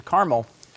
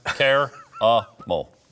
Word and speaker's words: caramel: How do you say this word right here? Car. Do you say that? Care? No. Car. Caramel. Caramel. Okay.